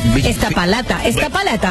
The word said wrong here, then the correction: Iztapalapa: Busquémonos cómo se dice el lugar bien. El lugar ese, dale. – Esta palata. – ¿O es esta palapa? A ver. – Esta pa. – ¿De dónde son ellos? – Esta palapa. – ¿Cómo se escribe? – Iztapalapa con Z. Iztapalapa.